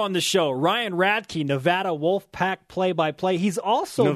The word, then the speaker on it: Nevada: It's pronounced Nevada.